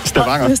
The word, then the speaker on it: Stavanger: Stavanger.